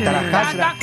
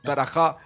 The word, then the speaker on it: Tarasca: De verdad, Tarascasa. Vale, bien. Ahora falta el estribillo. Ahí va, el daco. Oye, una cosa. He puesto el acento en la A de Tarasca.